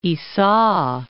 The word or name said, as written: Ica